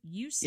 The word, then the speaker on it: Usenet: He has been called an accidental pioneer of online marketing, having sold one of the first ebooks on Usenet. Is that how you pronounce that? Usenet? – Yeah, Usenet, correct.